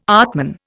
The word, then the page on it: atman: Indian